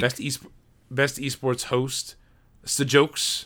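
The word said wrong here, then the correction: Sjokz: I think it's pronounced Socks. Shocks. – Oh, Socks. Shocks. – Shocks. – Anyways, definitely Sock. Definitely Shocks. – It's S-J-O-K-Z for those at home.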